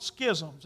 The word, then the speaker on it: schism: Billy, I looked that word up this week and you say it like you say scissors. And I've always called it schisms.